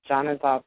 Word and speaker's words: Janazah: Janazah prayer. It's spelled J A N A Z A H.